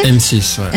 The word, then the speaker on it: M6: M6. Eh. M6, perché c'è scritto là come si pronuncia. – In scaletta c'è scritto tra parentesi, si legge M6.